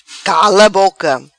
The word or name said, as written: Cala Boca